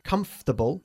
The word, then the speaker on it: comfortable: Comfortable, we don't pronounce the letters O-R. We say comfortable, okay? He took comfortable y no table.